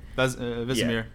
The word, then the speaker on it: Vesemir: Vesemir.